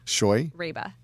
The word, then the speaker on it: Scheurebe: Scheurebe.